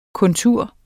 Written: kontur

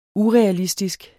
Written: urealistisk

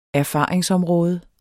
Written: erfaringsområde

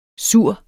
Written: sur

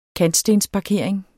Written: KANTSTENSPARKERING

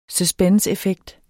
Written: suspenseeffekt

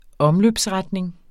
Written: omløbsretning

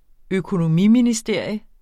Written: økonomiministerium

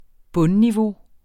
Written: bundniveau

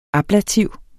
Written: ablativ

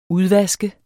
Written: udvaske

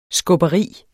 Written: skubberi

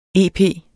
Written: ep

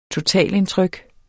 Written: totalindtryk

